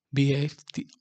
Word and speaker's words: BAFTA: Como cinco premios BAFTA, B-A-F-T-A, y un León de Plata a Mejor Dirección. ¿Te imaginas, güey? Pues no se logró en Oscar, pero sí en BFTA, BFTA, oh, lo dije bien, y en, y en y pinche León de Plata, güey, ¿te imaginas?